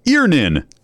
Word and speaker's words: Earnin: E-A-R-N-I-N is pronounced Earnin.